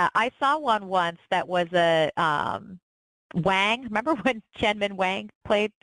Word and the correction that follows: Wang: Or, I can never say his name correctly. – Wong? – Played for the Nats. Wong. I always say it wrong.